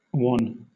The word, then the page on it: wan: British English